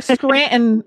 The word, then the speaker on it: Scranton: Scranton is spelled S-C-R-A-N-T-O-N, but we say Scranton.